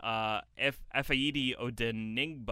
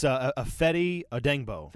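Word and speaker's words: Efe Odenigbo: they went and signed Efaidi Odenigbo. – Mm-hmm. – Did I pronounce that right? – It's Efaidi Odenigbo. – Thank you. Efaidi, yeah.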